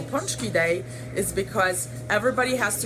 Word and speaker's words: pączki: So the proper pronunciation of the word pączki is in Polish. Pączki is many, one pączek. And the, you do not say pączkis or pączki, but it's pączki.